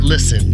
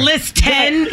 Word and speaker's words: listen: What letter is not pronounced in the word listen? – I Shoot, I don't know. – It's a T. – There's a T in there. – It's not list 10.